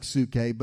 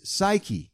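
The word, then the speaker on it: psyche (ψυχή): And your soul, the Greek word suke, it looks like suke, but I believe the correct pronunciation is psyche the mind, the will, the emotions, the personality.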